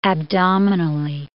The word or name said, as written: abdominally